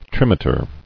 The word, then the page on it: Trimeter: trim·e·ter